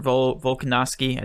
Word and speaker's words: Volkanovski: It's 45% for Volkanovski.